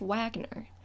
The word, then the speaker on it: Wagner: His name is spelled W-A-G-N-E-R, which, yes, in English, would be pronounced Wagner. But he's German, and in German, the W makes a V sound, so his name is pronounced Wagner.